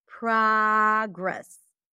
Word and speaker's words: progress: Object, conflict, contract, Oz and father, contract, and progress. Progress. In American English, it's pronounced with the open as in father.